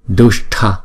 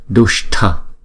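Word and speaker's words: duṣṭa: Chữ S này có dấu nặng này chúng ta đọc, để cong lưỡi lại đọc là sh, sh, sh, không? Và chữ TH nè. T có dấu nặng là t, còn TH là th, th, tờ hờ đó, th. Dushta, Dushta.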